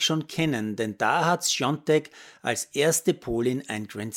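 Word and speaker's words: Świątek: Iga Natalia Swiatek geschrieben, spricht sich angeblich Sjontek aus. In Österreich höre ich allerdings dauernd Schwiatek.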